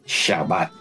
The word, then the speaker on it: Shabbat: Shabbat.